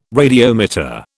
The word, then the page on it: radiometer: British English